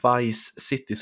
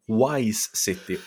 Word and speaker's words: Vice City: så har någon skrivit en recension där de tycker att podden är bra, men de undrar varför du uttalar Vice City som Wise City